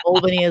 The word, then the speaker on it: Albany: You read it right. It's just pronounced that way. – Albany.